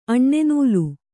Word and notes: ಅಣ್ಣೆನೂಲು: Kannada

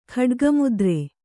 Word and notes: ಖಡ್ಗಮುದ್ರೆ: Kannada

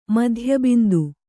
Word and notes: ಮಧ್ಯಬಿಂದು: kannada; madhya bindu

